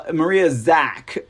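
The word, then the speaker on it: Maria Zack: Look at it is Maria Zach you know, yesterday I kept saying Maria Zucks. So thank you, people in the audience, for not uh, correcting and shaming me and making me feel stupid for saying her name wrong. I was like, Maria Zuck, Maria Zuck. I want to call her Maria Zuckerberg for some reason. Uh, Maria Zach.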